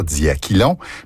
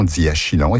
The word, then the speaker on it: diachylon: En principe, il faudrait dire diaquilon, mais la prononciation diachylon est acceptable.